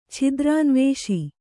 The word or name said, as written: ಛಿದ್ರಾನ್ವೇಷಿ